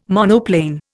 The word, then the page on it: monoplane: Indian